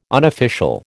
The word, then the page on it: unofficial: American English